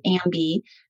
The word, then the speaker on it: AMBI: At the time we pronounced it AMBI. It's now been rebranded to AMBI, like I am bi.